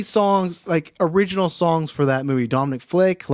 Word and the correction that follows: Fike: Like, his name is a Flick or Flake? – Dominic Fike? – Fike? Okay, I got his name wrong both ways.